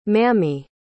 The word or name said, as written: mammie